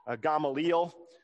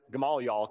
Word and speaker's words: Gamaliel: And I think his name is supposed to be pronounced Gamaliel, but if you're from the South, it's okay if you pronounce it Gamaliel, because that sounds a little bit more, kind of rolls off the tongue a little bit better.